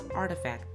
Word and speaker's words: RTFKT: RTFKT, pronounced artifact, is one of many labels blending the two landscapes.